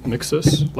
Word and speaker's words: Miksis: My name is Nathaniel Mixes. Last name is spelled M-I-K-S-I-S.